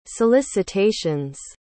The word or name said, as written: solicitations